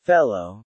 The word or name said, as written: fellow